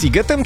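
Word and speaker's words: Sziget: CZ to jednak jest sigetem czy szigetem? – Oczywiście, ponieważ w języku węgierskim szy czyta się jak sy, a sy czyta się jak szy.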